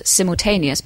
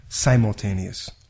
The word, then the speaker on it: simultaneous: And I think it's important for us to note here that because of my British background, I would pronounce this word as simultaneous. But how would you pronounce it? – The I in American English is different in this word. We say simultaneous. Simultaneous.